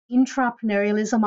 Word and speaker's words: intrapreneurialism: And just to clarify, it is intrapreneurialism. I'm not mispronouncing the word entrepreneurialism, but we'll explain more about that as we go through.